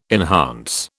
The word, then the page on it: enhance: British English